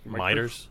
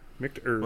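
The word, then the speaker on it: Michter's: Miters? – That's Miters, right? – Huh? Micters.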